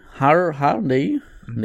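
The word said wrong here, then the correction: Hurney: ne Herney, weil er wird mit U geschrieben, da würde ich eher das Herney aussprechen. Ähm, eigentlich war er ja, fand ich eigentlich ganz gut ein Rebuild gemacht bis jetzt.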